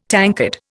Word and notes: tankard: Indian

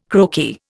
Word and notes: croaky: Indian

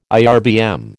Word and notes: IRBM: American English

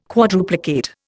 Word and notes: quadruplicate: Indian

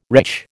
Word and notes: wretch: American English

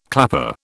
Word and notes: clapper: British English

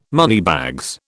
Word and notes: moneybags: British English

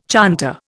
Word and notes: chanter: Indian

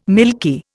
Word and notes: milky: Indian

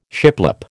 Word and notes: shiplap: American English

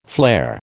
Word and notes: flare: American English

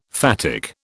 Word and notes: phatic: British English

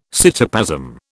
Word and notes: cytoplasm: British English

